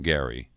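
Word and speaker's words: Gerry: David Daley wrote the book on gerrymandering, or do you say Gary? The guy who gerrymandering is named for pronounced his name Gary.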